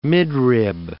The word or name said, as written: midrib